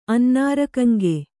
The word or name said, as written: ಅನ್ನಾರಕಂಗೆ